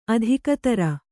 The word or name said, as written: ಅಧಿಕತರ